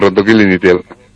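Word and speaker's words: Rentokil Initial: Muy amable, director de comunicación de Rentoquil Initial, ¿lo digo bien? – Lo dice bien, Rentoquil Initial.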